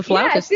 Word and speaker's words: flautist: My mom was a pretty good flautist. Is that how you say it? – Flutist? – Mm-hmm. Well, flautist? – That is correct. It's a flautist.